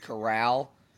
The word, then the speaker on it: Corral: By the way, it's pronounced Corral, like Golden Corral, who, by the way, came to his defense on Twitter, oddly enough.